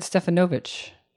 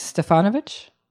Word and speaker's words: Stefanovic: On whose last name I gave the wrong syllable, the accent. I did the ch, but I said Stefanovic. And you, is Stefanovic? All right. Well, I want to get it right.